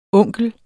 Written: onkel

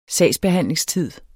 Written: sagsbehandlingstid

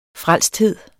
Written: frelsthed

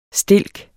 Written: stilk

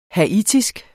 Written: haitisk

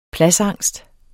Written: pladsangst

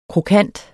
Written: krokant

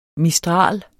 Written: mistral

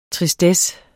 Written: TRISTESSEN

